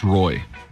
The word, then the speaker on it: ROI: I think it's pronounced Roy.